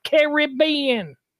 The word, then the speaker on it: Caribbean: No, it's Caribbean. It's very, very European in its pronunciation. Caribbean. Get the roll going, not Caribbean.